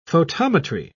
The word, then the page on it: photometry: British English